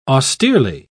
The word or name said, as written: austerely